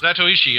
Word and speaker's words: Zatoichi: I mean, you look at Daiei, they did the Zatoichi, do I say that right? Zatoichi films? – Zatoichi, in fact. – Zatoichi, thank you.